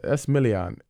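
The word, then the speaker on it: Esmailyn: How do you pronounce that, by the way? – Yeah, I tried my best. – You good job. – Esmilian. I said it right. – You did. I'd have butchered it.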